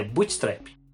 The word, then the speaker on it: Bootstrap: acho que eu até falei errado, deixa eu ver aqui, é Bootstrap, paradoxo de Bootstrap e também do princípio da autoconsistência de Novikov.